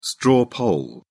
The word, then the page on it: straw poll: British English